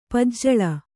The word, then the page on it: ಪೂಜಲ್ಲಿ: Kannada